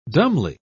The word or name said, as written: dumbly